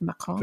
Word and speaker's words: Macron: Did you like my pronunciation of Macron? – Very nice, yes.